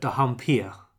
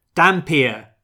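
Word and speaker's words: dhampir: I pronounce it dampier. I go full Udo Kier in this. I noticed some of the American cast called it dampier, and just sort of like, almost like saying diaper.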